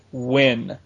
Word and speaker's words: Nguyen: And speaking of him, one of our listeners, Craig Skinner, has emailed us and says apparently that last name is pronounced Wynn. How N-G-U-Y-E-N is pronounced Wynn, I don't know.